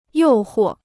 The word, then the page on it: 诱惑: yòu huò